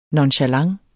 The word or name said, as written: nonchalant